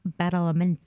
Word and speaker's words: Badalamenti: Paulette Badalamenti.